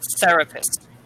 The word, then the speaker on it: therapist: Just make sure the difference between T, so T sound, and the TH sound. So make sure, therapist.